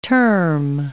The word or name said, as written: term